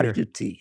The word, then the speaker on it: Cari tutti: Am I pronouncing it correctly? – Cari Tutti. Is there a show? – Cari Tutti. – All right, I can't do it. I can't pronounce Italian.